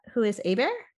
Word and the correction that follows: Herbert: Who is Frank Herbert is the correct answer. – Herbert? – I'm sorry. – I've never actually seen it. I was thinking H-E-R-B-E-R-T and I just mispronounced it.